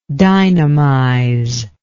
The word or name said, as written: dynamize